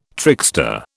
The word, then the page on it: trickster: British English